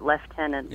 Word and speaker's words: lieutenant: I don't know either, but you know, the Scots pronounce lieutenant left-tenant.